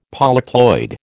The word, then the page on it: polyploid: American English